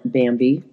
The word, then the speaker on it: Bambei: Sean Bambei, pronounced like the Disney movie, it's uh, spelled B A M B E I,